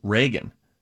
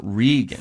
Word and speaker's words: Reagan: It's spelled Reagan. The same. – There's an R-E-G-A-N, and then there's an R-E-A-G-A-N. Apparently, they're both pronounced Reagan. – Yes. – Not Regan. – Fun fact. Okay. I have a neighbor boy named Reagan, R-E-A-G-A-N, and I have a neighbor girl named Regan, spelled the same exact way.